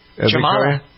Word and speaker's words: Jamala: All right, so anyway, next up, musically speaking here, the lady from the Ukraine who won the Eurovision Song Contest, Jamala, is that how you pronounce her name? – Jamala? Yeah.